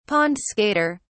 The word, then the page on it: pond-skater: British English